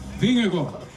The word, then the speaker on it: Vingegaard: Vingegaard.